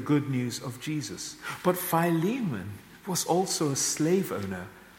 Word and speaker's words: Philemon: And this was the situation that Paul confronted Mandy's now changed my pronunciation of this. Philemon, I call him Philemon with in our epistle.